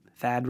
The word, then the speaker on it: Thad: Stay-at-home Thaddeus ruins the clever and fun pun of Thad rhyming with dad because I'm a stay-at-home dad.